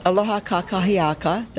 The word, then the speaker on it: aloha kakahiaka: Aloha kakia... Gotta pronounce it correctly. Aloha kakahiaka, that's good morning in Hawaiian.